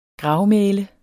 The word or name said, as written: gravmæle